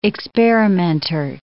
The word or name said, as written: experimenter